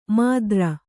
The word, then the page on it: ಮಾದ್ರ: mādra